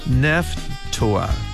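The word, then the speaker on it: Nephtoah: PH가 있습니다. 그래서 넵도아, 넵도아, T예요, T, T-O, 넵도아. 한 번 더요. 넵도아, 넵도아를 넵도아 이렇게 발음해 주시고, 흰놈의 아들 골짜기, 골짜기는 벨리고 흰놈은 히남 이렇게 발음합니다.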